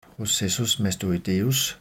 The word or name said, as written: processus mastoideus